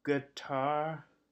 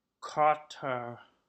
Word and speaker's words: Qatar: Note, closest approximation of native pronunciation is Gatar or Kotar.